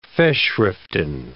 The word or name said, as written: Festschriften